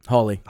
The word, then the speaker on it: Hawley: Senator Hawley, I think I said that right. – Hawley.